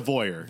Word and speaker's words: voyeur: So you're like, is it pronounced? Vo- it's a voyeur. – Voyeur. – It's definitely a voyeur.